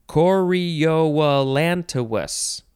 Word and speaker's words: chorioallantois: I really think that's how it's pronounced, but I don't know if that's how exactly I would say the end. Anyway, it is spelled C-H-O-R-I-O-A-L-L-A-N-T-O-I-S. Chorioallantois. See, the end looks like French.